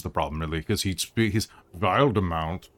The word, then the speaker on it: Wildemount: Is it Wildemount or Wildemount? I can never know how to pronounce Wildemount or Wildemount. It's Wildemount, right? – Usually Wildemount, yes.